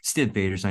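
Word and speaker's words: Stiv Bators: Steve Bader's, I know is how it's more often pronounced.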